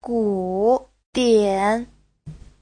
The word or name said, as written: gu3dian3